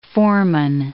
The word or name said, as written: foreman